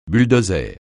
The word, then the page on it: bulldozer: francais